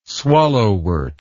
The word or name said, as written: swallowwort